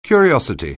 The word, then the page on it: curiosity: anglais